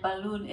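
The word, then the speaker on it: Balún: B-A-L-U-N, Balloon. It's balloon. – Spanish for balloon.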